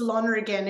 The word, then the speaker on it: Loneragan: Is that how you say it? It's Loneragan. It's a mouthful.